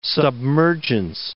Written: submergence

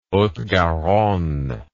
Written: haute garonne

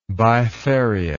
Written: bifariously

